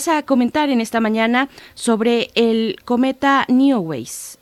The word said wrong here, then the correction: Neowise: si lo estoy pronunciando bien, si no, tú corrígeme por favor, ¿de qué se trata? – Sí, bueno, estas palabras así extrañas es válido pronunciarlas como podamos. Okay. pues sí, yo les quiero hablar de Neowise, eh, que está captando nuestra atención desde hace ya varias semanas.